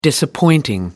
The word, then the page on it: disappointing: American English